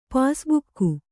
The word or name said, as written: ಪಾಸ್ಬುಕ್ಕು